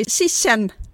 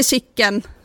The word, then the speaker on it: Chichen: Det är Colosseum i Rom och det är Chichen Itza i Mexiko. Inte Chicken som många säger.